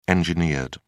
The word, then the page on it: engineered: British English